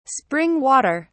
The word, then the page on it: spring water: British English